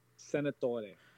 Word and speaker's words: Senatore: Say the last part. I mean, I guess you'd say it like that. – Senatore. – Yeah, I guess you'd say it like that. It's your last name, but anyone I hear just says Senator. I'm just like, it's not the same as you.